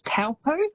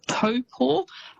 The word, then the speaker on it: Taupo: So quite often you'll hear that said as Taupo. – Yes. – It's almost how it's spelt, but that Taupo is actually pronounced Taupo.